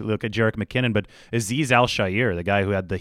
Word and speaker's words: Azeez Al-Shaair: I think he pronounces it Azeez Al-Shaair. – Azeez Al-Shaair. – Al-Shaair. It's Al-Shaair.